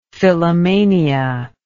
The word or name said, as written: phyllomania